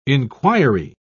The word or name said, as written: inquiry